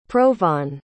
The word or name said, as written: provan